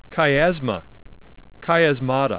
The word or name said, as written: chiasma